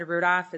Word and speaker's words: Rudolph: For the record, my name is Brenda Rudolph. It's spelt wrong in the agenda as well. It's like the reindeer, PH is at the end.